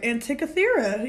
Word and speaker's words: Antikythera: The Antikythera. I think I said that right. Antikythera.